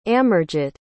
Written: amarjit

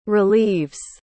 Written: relieves

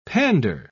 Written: pander

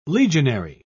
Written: legionary

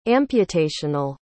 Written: amputational

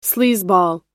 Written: sleazeball

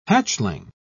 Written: hatchling

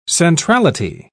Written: centrality

